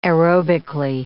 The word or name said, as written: aerobically